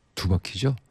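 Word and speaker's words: 두 바퀴: 혜은이의 띠띠 빵빵, 그리고 노래하는 김광석의 뒤바퀴로 가는 뒤바퀴? 뒷바퀴 아니고 두바퀴죠? 두바퀴, 큰일 났다. 발음 안 된다. 다시 해볼게요. 김광석입니다. 두바퀴로 가는 자동차.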